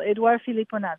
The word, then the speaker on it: Edouard Philipponnat: And this is Edouard Philipponnat, is that how you say it? Yeah, you said the name very well. Edouard Philipponnat, that's right.